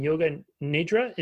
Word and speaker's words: yoga nidra: She's passionate about wellness, self-care, and spiritual development, holding certifications in hypnotherapy and yoga nidra.